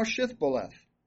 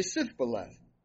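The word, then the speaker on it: Shibboleth: And if he said, nay, then said they unto him, say now Shithboleth. And he would say Sithboleth, for he could not frame to pronounce it right.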